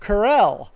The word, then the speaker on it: Carell: Steve Carell.